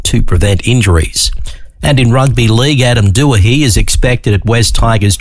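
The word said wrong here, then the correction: Doueihi: It's actually pronounced Dewey, not Doohehe.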